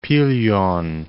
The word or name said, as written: Pilion